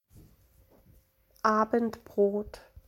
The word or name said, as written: Abendbrot